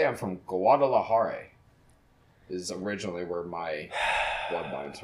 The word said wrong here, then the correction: Guadalajara: That's awesome, but it's Guadalajara. You said it like Peggy Hill would pronounce it. Guadalajara.